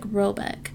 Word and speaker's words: Roback: The manor house, or Hergard in question, is to be called Roback, pronounced something like Robek, though that is not its name.